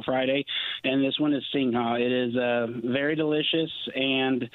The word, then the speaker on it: Singha: Singha.